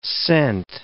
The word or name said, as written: scent